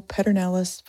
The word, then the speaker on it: Pedernales: So, for the Texans listening in, that's Pedernales.